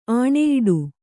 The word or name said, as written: ಆಣೆಯಿಡು